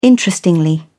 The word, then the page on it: interestingly: British English